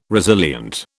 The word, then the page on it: resilient: British English